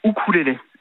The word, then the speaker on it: ukulélé: Alors normalement on dit ukulélé. Le U en océanien se prononce ou, bon. Donc normalement on devrait dire ukulélé, okay. C'est la prononciation anglo-saxonne du mot.